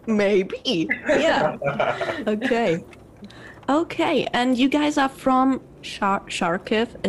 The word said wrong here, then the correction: Kharkiv: Is that right? Right pronunciation? Charkiv? Yeah. – You know, almost, but it's Kharkiv. – Oh, Kharkiv.